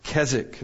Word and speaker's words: Keswick: It looks like Keswick, but it's British. So it's pronounced Keswick.